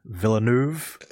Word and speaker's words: Villeneuve: I can never say his name. Villeneuve? – Yeah. – There we go.